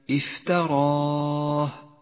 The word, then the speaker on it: افتراه: لاحظ كيف رسمت كلمة افتراه. لاحظ هذه الياء الرمادية، فإنها كتبت ولا محل لها في اللفظ، وهي من زيادات الرسم العثماني، ميزها لك مصحف التجويد باللون الرمادي إشارة إلى أنها لا تلفظ.